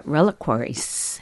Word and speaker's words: reliquaries: What came of it is some stunning pieces of art, and as Karen mentioned, the reliquaries.